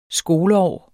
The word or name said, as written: skoleår